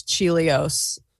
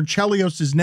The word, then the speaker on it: Chelios: I've always said Chris Chelios. That is my bad. – You made him a cereal. – His daughter is actually my age. She went to Northwestern. I always thought it was Chelios.